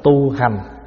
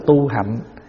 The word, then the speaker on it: hạnh: Cho nên thường thường mình đọc là tu hành. Chữ hành đó với chữ hạnh viết giống nhau. Chữ Hán là viết chữ hạnh cũng được, mà đọc là chữ hành cũng được, chữ hạnh cũng được. Thì hành là gì? Hành là tu hành đó. Mình nói đúng là tu hạnh.